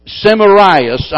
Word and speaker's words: Semiramis: Nimrod, when he died, Queen Semiramis, I believe is the way you pronounce her name, she claimed that he was the sun god.